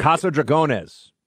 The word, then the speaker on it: Casa Dragones: You can pronounce it better. – Casa Dragones. – Dragones. All right. I would basically pronounce it Casa Dragons, right? Dragones.